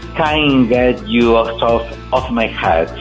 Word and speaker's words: Can't Get You Out of My Head: Su álbum y su éxito Can't Get You Out of My Head.